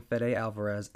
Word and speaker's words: Fede Alvarez: Uh, there isn't much we know about the upcoming Fede Alvarez Alien film. Did I say that right? Yes, you did.